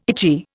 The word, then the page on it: itchy: Indian